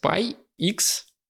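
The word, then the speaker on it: Spy × Family: А, Spy Family называется mm-hmm. – Spy X, который не читается Family.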